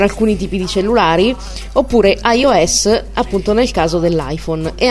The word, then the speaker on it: iOS: Nel caso dell'iPhone appunto questo sistema si chiama iOS, scritto iOS, appunto si pronuncia iOS, e ogni tot tempo esce un aggiornamento appunto di questo sistema operativo.